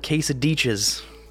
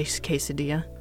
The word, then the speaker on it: quesadilla: why don't we go get a couple of quesadichas? – Oh, that's even worse. – Yeah, it is. I guess, well, there are some places that pronounce the double as a j, so I guess it's not at, but yeah, I still would be like, it, just say quesadilla.